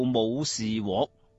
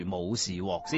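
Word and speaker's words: 武士彟: 她这位父亲叫做武士獲，这个獲字有点复杂的，而且读音方面呢有啲争议嘅，有人话读若，有人话读獲，咁暂时我哋读住武士獲先。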